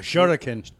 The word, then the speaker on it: shuriken: She got a shrooken. I said, what the fuck is a shrooken? Am I saying it right? – Oh, a shuriken. – See? She says it funny like shuriken.